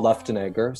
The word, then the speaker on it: Luftenegger: My name is long, so everybody always asks, how do you say Paul Lufton, blah, blah, blah? So, but it's Paul Luftenegger.